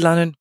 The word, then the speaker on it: Lannan: I'm Richard Lannan, rhymes with Cannon, and this is the Glazing Insider Industry Podcast.